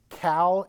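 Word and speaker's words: Qal: It's what we call in grammar, again, elementary school, middle school grammar that might cause us to, you know, shake a little.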